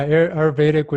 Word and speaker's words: Ayurvedic: It was more spiritual perhaps and more, what's Ayurvedic medicine? Is that how you say it? – Yeah, Ayurvedic would be the medicine it's a big medicine over there.